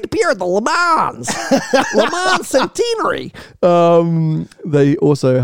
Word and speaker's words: Le Mans: It's not Le Mans, because it's, I don't think, I am not French, but it's not Les Mans, and you don't pronounce the S at the end. It's Le Mans.